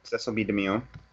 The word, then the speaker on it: Cecil B. DeMille: This one's very, very short, but just the DeMille building, named after, is Cecile DeMille? I forgot what it is. – Cecil B. DeMille. – Thank you. Yes. I was not sure how to pronounce his name.